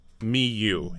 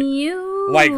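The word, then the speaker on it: Myuu: It is pronounced Miu. He says it on his website. – Miu. – Like me and you.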